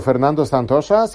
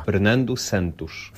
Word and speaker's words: Fernando Santos: Nazwisko Fernando Santosza sprawia niektórym problemy. – Filipe Santos. – Oto poprawna wymowa. – Fernando Santos.